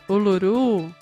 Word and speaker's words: Uluru: Uluru, a pedra lá do meio.